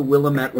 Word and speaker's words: Willamette: Willamette River. – Time out. It's Willamette River. Willamette? And also, you don't get... I know. It's because... Yeah, it's Willamette.